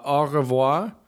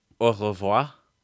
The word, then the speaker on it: Au revoir: Au revoir. I don't say it right. Can you say it? You probably say it better. – Au revoir.